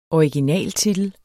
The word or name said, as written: originaltitel